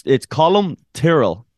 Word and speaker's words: Colum Tyrrell: I guess it's hard to say your name. – Yeah, it's Colm Tyrell. But if you Tyrell. – Say, Tyrell, yeah. – I can't say Tyrell? – Yeah, you got it. Perfect.